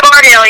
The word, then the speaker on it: Bardill: Yeah, Bardill.